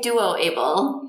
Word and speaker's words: DuoABLE: And today we're going to be discussing your work on developing the ABLE and now DuoABLE, or is it pronounced Doable Project? – We say DuoABLE in my lab.